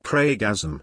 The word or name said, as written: kreygasm